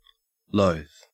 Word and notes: loath: Australian English

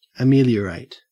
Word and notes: ameliorate: Australian English

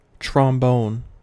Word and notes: trombone: American English

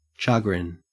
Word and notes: chagrin: Australian English

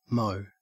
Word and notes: mo: Australian English

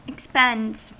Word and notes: expends: American English

